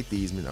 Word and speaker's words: Theismann: Some people say Theismann. I understand that's how you actually say his name.